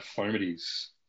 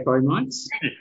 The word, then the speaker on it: fomites: Yeah, I'm still getting my head around fomites. – That's right. Well, we were both going to say fomites because it's spelt FOMITS. Sorry, ITES.